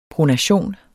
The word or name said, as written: pronation